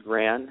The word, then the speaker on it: Gran: Okay, so my first name is Steven, and my last name is Gran,